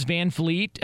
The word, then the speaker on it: VanVleet: As much as Fez loves VanVleet, and I can't even pronounce his name. I keep putting an F there instead of a V.